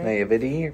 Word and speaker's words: naivety: I think maybe it was coming from, like, a place of, is it naivety? – I think you said it right. – Naivety, right? – Yeah, being naive.